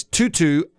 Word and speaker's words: Tutu: His name is Tutu Atwell. Wow. His first name is spelled T-U-T-U. – Like the ballerina thing.